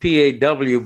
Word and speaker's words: paw: I think it's spelled P A W, but you have it to is. pronounce it in that very cute way, like paw.